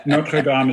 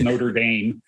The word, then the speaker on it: Notre Dame: Notre Dame is a cathedral in France. Notre Dame is a school in America.